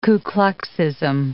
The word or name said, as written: Ku Kluxism